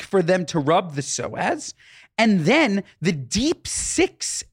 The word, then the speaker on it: psoas: The psoas.